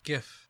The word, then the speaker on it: GIF: There's so many people that pronounce it GIF to this day, but it literally stands for graphic interval format, right? Yeah. Graphic, g, not j. How do you say M-I-R-R-O-R? – Mirror.